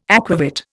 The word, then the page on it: aquavit: Indian